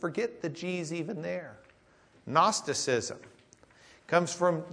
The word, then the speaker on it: Gnosticism: Gnosticism. The G is silent. Do you know why? For the same reason the K is silent in Kno. G-N-O was their K-N-O, from which we get Kno, because they gnu about it back then.